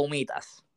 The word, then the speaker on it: humitas: Entonces, Kido Works, empanadas o humitas, no sé si es así, humitas, ¿cómo se dice? – Eh, humitas, sí, está bien dicho.